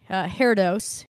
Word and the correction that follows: Herodotus: The Greek historian, Herodotus.